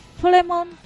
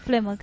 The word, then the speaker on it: Fleamont: Se llamaba Flemont, o Fleamont se escribe, Flemont creo que, Potter.